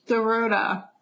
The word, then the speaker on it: Dorota: I got a picture with Nate, Chuck, Dorodo. – Oh, my God. – How do you say it? Dorota.